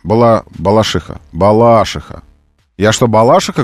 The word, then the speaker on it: Балашиха: Словарь «Дарья Резенталь», слово «балашиха», ударение на букву «и». Балашиха. А вы делаете на букву «а». Балашиха.